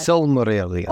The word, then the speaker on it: Silmarillion: Because what I have come to learn over these like 5 years of doing this podcast is that the Cimmerillion, am I saying that right? Oh my God. – Silmarillion.